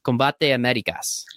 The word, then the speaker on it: Combate Americas: Combate Americas.